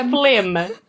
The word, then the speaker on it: phlegm: Or phlegm. Phlegm. But okay, you know how the word phlegm is spelled with like a silent G or whatever, and it's just really confusing.